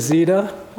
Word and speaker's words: Zita: If we haven't met yet, my name is Zita. That rhymes with PETA, if that's easier to remember.